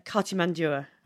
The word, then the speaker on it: Cartimandua: Cartimandua.